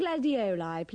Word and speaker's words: gladioli: Pay attention to the pronunciation of the second I in gladioli. – I'd like a dozen gladioli, please.